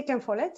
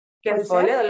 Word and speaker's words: Ken Follett: Porque, bueno, una lectora, por ejemplo, que me dijo que le había recordado a, ¿cómo se dice? A, Ken Follett se dice? Sí, Ken Follett, sí. – Ken Follett, Los Pilares de la Tierra.